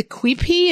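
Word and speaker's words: quipu: And you talked about, I believe it's the Quipi, is that's how it's pronounced? – Oh, yeah, yeah, yeah.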